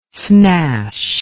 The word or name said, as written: snash